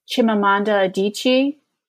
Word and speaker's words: Chimamanda Adichie: Chimamanda Adichie. Did I say her name correctly? Mm-hmm. – Yeah, Chimamanda Ngozi Adichie.